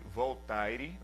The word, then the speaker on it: Voltaire: Por exemplo, Voltaire, um filósofo muito conhecido, na verdade se escreve Voltaire né, em português, mas se pronuncia Voltaire.